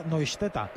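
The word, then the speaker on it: Neustädter: Uno de los centrales que se escribía, era muy divertido, se escribía Neustadter, pero se pronuncia Neustetta.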